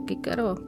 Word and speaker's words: Cicero: Takže eh, v západní Evropě se, se, se vyslovuje kikero, kajsar, takhle.